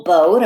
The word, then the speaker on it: bow: Bow only has two letter sounds that we can hear. B-O. B-O. But it's spelled B-O-W, just so you know.